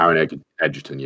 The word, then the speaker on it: Taron Egerton: I actually think Is it Taron Egerton? Is that how you pronounce it? – Taron Egerton, yeah.